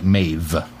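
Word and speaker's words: Maebh: I thought it was Maeve. Like Maeve with a V, but it's not, apparently.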